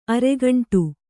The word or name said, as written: ಅರೆಗಂಟು